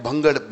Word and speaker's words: भांग: सुनो, वो एक जगह एक भंगड़ भंगड़ कटे हो गए, भंगे भंग पी थी उन्होंने, भंग भंग पिए, भंग समझते हो नहीं, भांग भांग भंग नहीं भांग, ये बड़ी प्रोनाउंसिएशन बड़ी जबरदस्त है इसकी, भंग नहीं भांग, हमारे भंग कहते हैं उधर इसको।